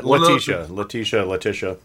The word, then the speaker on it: Letitia: Now, the New York Attorney General Letitia James, and I hope, or Letitia. I butchered her name, I'm certain. – Yeah, Letitia.